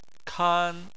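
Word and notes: kan: tone 1

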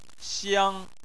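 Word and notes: xiang: tone 1

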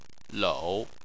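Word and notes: lou: tone 3

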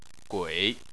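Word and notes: gui3: tone 3

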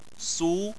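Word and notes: su: tone 2

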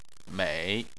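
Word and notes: mei: tone 3

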